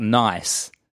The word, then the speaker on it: Nice: Now, this works written down because Nice is spelt nice. – Yeah. – But I believe pronounced Nice, right?